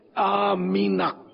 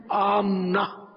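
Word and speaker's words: آمِنہ: آمینہ، میم کے نیچے زیر ہے آمینا، ہمارے یہاں میم کو ساکن پڑھتے ہیں عام طور پہ، آمنا بولتے ہیں، تو صحیح تلفظ ہے آمینا، یہ عربی ہے آمینا.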